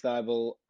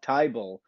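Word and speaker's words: Thybulle: He had Ben Simmons or Matisse Thybul, or if you want to pronounce it wrong, Thybul.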